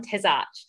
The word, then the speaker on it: Tesarsch: I am very excited because this week on the podcast we have John Tesarsch.